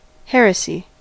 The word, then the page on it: heresy: /ˈhɛɹəsi/